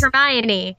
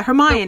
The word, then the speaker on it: Hermione: It's like it's Hermione and not Hermione. – Wait, it's Hermione? – No, but everyone I know mispronounced her name until they heard it. It's Hermione. – Yeah, Hermione.